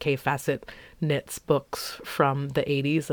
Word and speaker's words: Kaffe Fassett: If that's apparently how you pronounce his name, if you look at it and you think it's Kaif Facet, you are not alone.